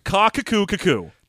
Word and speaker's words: Qaqquqqu: So this is spelled Q-A-Q-Q-U-Q-Q-U. And yeah, we spent some time while getting ready to do this episode discussing the pronunciation, and we settled on Lord Kakukku.